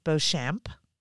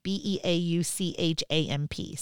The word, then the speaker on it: Beauchamp: So you guys can look up Sarah, and your last name is pronounced Beauchamp, right? – Yep. – So Beauchamp, Sarah Beauchamp.